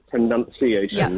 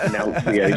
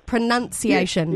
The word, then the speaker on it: pronunciation: Literally, it's pronunciation, not pronunciation. – No, I'm so with you. Yeah. It's N-U-N, none. Pronunciation.